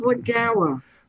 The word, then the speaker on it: Lagoa: So, and then the two leading contenders appear to be Amy Coney Barrett, Circuit Court Judge, and Barbara Lagoa. How do you pronounce it? – It is Lagawa.